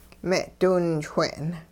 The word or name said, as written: me'diwinchwe'n